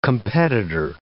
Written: competitor

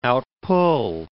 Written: outpull

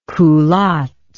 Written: culottes

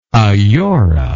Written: aiora

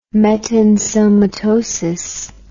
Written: metensomatosis

